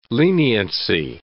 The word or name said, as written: leniency